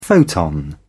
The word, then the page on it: photon: British English